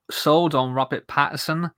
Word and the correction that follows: Pattinson: or Pattinson, I should say. I always say his name wrong. It's I-N as opposed to E-R in the middle of his name.